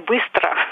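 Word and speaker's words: bistra (быстро): La prononciation est bistra.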